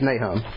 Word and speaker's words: Naaman: I say Naaman? Oh, that's the Greek. Greek pronunciation. That's how we pronounce Greek. Yeah, it's Naaman. Thanks.